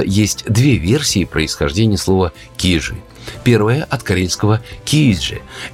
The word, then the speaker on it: Кижи: И в этом случае ударение в конце. Но мы будем говорить «кижи».